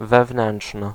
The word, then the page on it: wewnętrzny: [vɛˈvn̪ɛ̃nt͡ʂn̪ɨ]